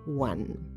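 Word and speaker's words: won: Se pronuncia igual el número 1, one, que el pasado de win, one.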